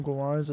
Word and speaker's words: Guimarães: I think Eddie Howe has a vision of how he wants to play, and I think that Bruno Guimarães, or however you say his name, Guimarães.